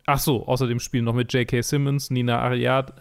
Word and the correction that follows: Arianda: Arianda, äh, Tony Hale, Alia Shak Shokkat, Jake Lacey, Linda Levine und so weiter und so fort und viele mehr.